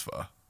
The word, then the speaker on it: pho: Before anybody thinks anything different, I know it's pronounced pho.